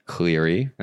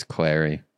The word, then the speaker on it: Clary: You know what's funny is I had Laura Clary on my podcast, and I mispronounced her name. – What'd you say? – Cleary. – It's Clary? – It's Clary. That's a tough one, though, because I just always pronounced her name wrong.